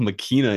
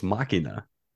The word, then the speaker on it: Machina: I've never heard it called Machina in my life. It's Machina.